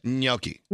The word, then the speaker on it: gnocchi: Gnocchi.